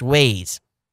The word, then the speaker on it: Waze: The WAZ name is pronounced Waze. It's W-A-Z-E. It's WAZ. You know, I understand Waze, but it's W-A-Z as Waze.